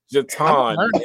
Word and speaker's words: Je'Taun: Jatan. Just enunciate it with me. Jatan.